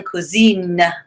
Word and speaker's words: cousine: And then for a girl cousin, cousine. – Cousine.